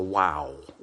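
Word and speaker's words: waw: And it's a letter W, a wow, or a vav, depending which way you want to pronounce it.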